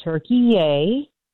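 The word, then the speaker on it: Türkiye: Let me correct you. It's Turkey yay. – Turkey yay. – Yes. – What's that mean? – That's how it is pronounced. I had to look this up last night because... The announcers kept saying, "Turkey yay, Turkey yay." I looked it up because I'm like, what are we doing here? In 2022, Turkey, the country, went to the UN and had their English pronunciation changed to Turkey yay so that it didn't sound like the bird turkey.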